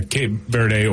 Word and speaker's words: Cape Verde: Yeah, Cape Verde, or in Portuguese, it's Cabo Verde.